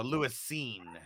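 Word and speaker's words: Lewis Cine: Okay, and Marty said it's pronounced scene. Okay, hopefully he can come back stronger. So, Lewis Scene.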